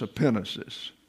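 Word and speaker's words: epinesis (ἐπίγνωσις): Then when you have a situation where you have a gamma and a nu together, you can't pronounce the G, you have another N in the transliteration in the English. Got that? So that's epinesis.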